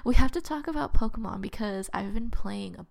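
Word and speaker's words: Pokémon: Well, it's Pokemon because in Japanese, K-E is ke. – Mm. – And so, like, Americans say Pokemon, and ki is K-I in Japanese. So that's why it's Pokemon.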